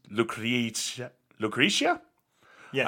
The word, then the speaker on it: Lucretia: They cover the Sisters of Mercy song, Lucretia? – Yes. – I have no idea how that's pronounced. – Lucretia, yes.